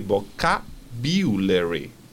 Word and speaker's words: vocabulary: En vocabulario, esa U de vocabulario no se dice u, es iu, con lo cual hay que decir vocabulary. ¿Ok? Entonces, nos llevamos la entonación al principio y es vocabulary.